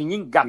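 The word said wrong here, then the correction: Nheengatu: Nhengatu, né? Nhengatu. – Nhengatu, é.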